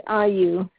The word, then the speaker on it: Ayu: My first name is Ayu.